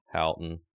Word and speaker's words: Houghton: So you spent 17 years at Houghton. Am I saying that right? – Yes, you are.